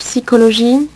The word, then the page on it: psycho: French